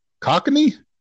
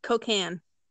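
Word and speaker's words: Cocagne: I want to say cocaine, but I'm not sure that's how you pronounce it. Cockney? – No. God damn it. – it's cocaine. – Cocaine.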